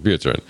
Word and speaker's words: Buterin: Buterin.